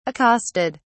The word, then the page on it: accosted: British English